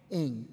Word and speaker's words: Ng: Please correct me on the pronunciation of Kim's last name. – Ng.